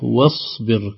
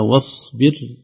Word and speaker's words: واصبر: الصاد الصغيرة هذه إذا وضعت فوق الألف وقبلها شيء لا تنطق أبدا، تصبح واصبر كأنها واو صاد ما في ألف تسقط. – نعم نعم. – إذا نقولها واصبر.